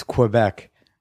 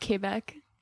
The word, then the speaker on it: Quebec: Pronounced Quebec. – No, it's not. It's Quebec. – We've been over this. It has a little accent on the E.